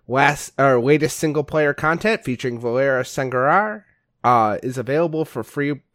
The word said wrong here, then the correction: Sanguinar: I don't know how you pronounce that. It's like sanguin, It's sanguinar. – Sanguinar, yeah, like blood.